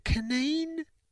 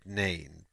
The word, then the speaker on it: Kneen: That, you know, because her surname's spelled K-N-E-E-N. And I said, hey, is it Kaneen or is it Neen? And I have such a bad track record of pronouncing surnames or first names or any words. They're all tough, these words. And she said Neen, but that she gets it all the time.